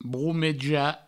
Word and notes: bromejar: broumeja

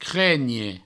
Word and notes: crenhe: cregne

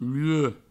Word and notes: luec: provençal

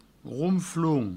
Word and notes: ronflon: rounfloun; Provençal

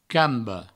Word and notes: camba: provençal; cambo

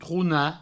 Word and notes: tronar: trouna

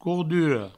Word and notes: cordura: courduro

